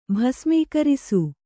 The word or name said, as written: ಭಸ್ಮೀಕರಿಸು